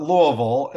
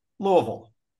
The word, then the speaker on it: Louisville: We call it Louisville, and the way that I help people fool the residents is it's basically three syllables. The first is like the guy's name, Lou, like Lou Gehrig. Like you're thinking what you're going to say next. And then vol, like the first syllable in vulture. So you put together, you got Lou, vol, Louisville.